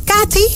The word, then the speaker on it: Kati: Hey, Kati, zeg ik het goed? – Ja, dat is helemaal goed.